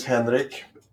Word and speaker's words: Henrik: My name is Henrik, Swedish pronunciation.